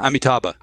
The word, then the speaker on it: Amitabha: But I want to chat with you a little bit about the concept of paradise, particularly the idea of the Amanita's Buddha. Do you know, how do you pronounce it? – Yeah, Amitabha.